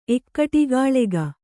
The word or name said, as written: ಎಕ್ಕಟಿಗಾಳೆಗ